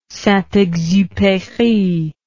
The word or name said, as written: saintexupery